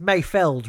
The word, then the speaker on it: Mayfeld: It's Mayfeld, right? I keep pronouncing it wrong. – Yeah, Mayfeld. Yeah, I think I've written down Field in all my notes, but I'm looking at IMDb here and it's Feld.